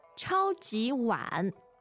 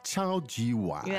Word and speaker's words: 超级碗: How do you say Super Bowl while we're on it? – 超级碗. 超级碗. – 超级碗. – Yeah, very straightforward. – Super and bowl.